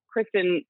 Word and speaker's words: Kristin: I always joke about that because then everyone spells Kristin. I spell my name with two I's instead of like I-N instead of E-N.